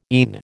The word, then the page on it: ENE: American English